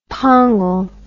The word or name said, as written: pongal